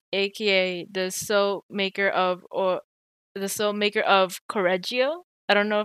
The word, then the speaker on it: Correggio: I think you have to roll the R. – Correggio. – Correggio. Okay, so I'm kind of unrelated, but in Italian, when there's two of the same syllable, there's kind of a stop in between. So it would be Correggio.